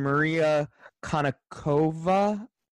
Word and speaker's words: Maria Konnikova: He is – it was like him and Maria Konnikova. – Mm-hmm. – Did I say that correctly? – Yeah. – Oh, nice. – I feel like that's the first time you've gotten that right.